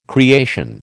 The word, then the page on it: creation: American English